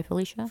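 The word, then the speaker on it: Filicia: Not sure I pronounced his last name, but Felicia, I believe. – Felicia? Is that how you pronounce it? – Isn't it just like Felicia, like by Felicia? – Felicia?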